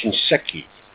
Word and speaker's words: Shinseki: And we can gather them up and send them in to that Mr. Shusiki. – What's his name? – Well, I don't know. It's something that starts with an S is the only thing I can tell you. – It's pronounced Shinseki.